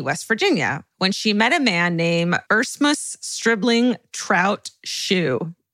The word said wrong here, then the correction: Erasmus: Erase, his first name is spelled E-R-A-S-M-U-S. – Erasmus.